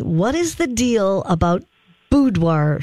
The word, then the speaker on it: boudoir: I don't, boudoir.